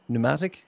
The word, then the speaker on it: pneumatic: So it had a pneumatic. How do you say that? P-N-U? – Pneumatic. – Okay. Pneumatic gun, which was 11 feet long.